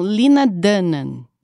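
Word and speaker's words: Lena Dunham: em inglês, eles falam Lina Dunnan. Eu achei que a gente pronunciava o R, mas eles não pronunciam.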